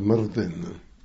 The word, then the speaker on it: Myrddin: Or if it's Myrddin, pronounced Myrddin, but Myrddin mostly, and Mervyn I was known as in the army.